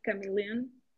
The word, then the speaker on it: chameleon: Is it that the correct way to say it, chameleon? – Yeah.